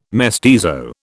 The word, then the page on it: mestizo: British English